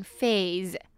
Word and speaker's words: phase: 再我们来看 phase, phase呢, 指的是某一个阶段, 我们通常会说 at an early phase, 就是在某一个早期的阶段, 会这样讲, 那 phase呢, ph发 f的音, se呢, 则是发z的音, phase, phase, 阶段, 最后我们来看 premature, premature呢, 指的是比预期早的, 或者是早产的, 那它就是 mature, 成熟的这个字, 再加上 pre, premature 我们来看 mature这个字它是M-A-T-U-R-E 但是跟nature这个字的发音完全不一样 M-A-T-U-R-E的M-A呢是发耳的音 mature mature OK so premature是指比预期早的或者是早产的像是a premature baby 就是早产儿那如果讲一个人很immature呢则是指他非常不成熟 premature immature mature OK, 我们最后来再来看一次例句 Genetics is in all that drives hair loss.